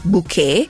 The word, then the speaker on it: Bucket: Si intitolava Keeping Up Appearances, andata in onda dal 1990 al 1995, perché la protagonista, Hyacinth Bucket, anzi lei dice che il suo cognome si pronuncia Bouquet,